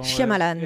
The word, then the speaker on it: Shyamalan: donc, donc, comment on fait déjà pour prononcer son nom dans Shyamalan. – Hein. voilà, voilà.